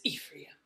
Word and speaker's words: Ephraim: I think it's Ephraim. – Ephraim. – But honestly, I don't know if that was my mom's way of saying it. – So. Oh, okay. – That's how I pronounce things in the Bible the way my mom did, because she always read it to us. – And she said it with such confidence that she was like, it is Ephraim. – Yes, and I was like, okay, it is. – That is how you say it.